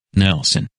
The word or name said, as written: Nelson